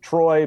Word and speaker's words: Treu: So it's Troy, but it doesn't look like that when it's spelled out.